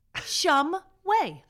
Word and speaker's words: Shumway: Shumway. I'm like, really? It's phonetic. Shumway. I guarantee you. It's spelled exactly like it.